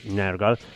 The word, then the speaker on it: Nergal: Vabbè, quello che è, comunque bel pezzo, bello, bello cattivo, Nergal, come giustamente mi correggeva la Babi, si pronuncia Nergal.